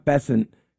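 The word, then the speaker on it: Bessent: But Scott Bessin, and that's how you say his name, Scott Bessin has a secret plan to crush the speculators.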